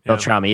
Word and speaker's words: Beltrami: Mac, what are your thoughts about Halloween H2O and the whole, you know, Ottman versus how do you say Marco's last name? Beltrami. Beltrami, yeah.